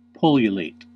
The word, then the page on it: pullulate: American English